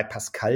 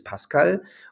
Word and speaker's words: Pascal: Das Deutsche ist Pascal und die Franzosen sagen Pascal. Ja, wir sagen Isabelle und der Franzose sagt Isabelle. Der wartet ein bisschen, bis er die Betonung macht, weil er die Kraft nach hinten raus nochmal verwendet. Und es ist ja das eine nicht richtiger als das andere, aber es ist schön, wenn man sich genau damit befasst, glaube ich.